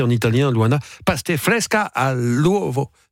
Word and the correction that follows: pasta fresca all'uovo: Alors j'étais bien parti. – Paste all'uovo. Pasta fresca all'uovo.